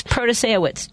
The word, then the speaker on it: Protasiewicz: And by the way, the name is Protasiewicz.